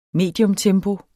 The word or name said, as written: mediumtempo